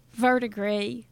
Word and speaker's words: Verdigris: Verdigris. What did I say? – Oh, sometimes mostly people pronounce the S, but it's silent.